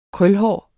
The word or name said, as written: krølhåret